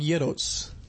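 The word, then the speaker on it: gyros: But I call them Yeros. – Yeah. – Like the Greeks. Chris D taught me how to say it.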